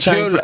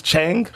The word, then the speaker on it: Chang: Who's Chang? And his name's Chang? – Chang! – Chang. – Is it pronounced Chang? – You gotta say Chang. It like Dave Chappelle said it.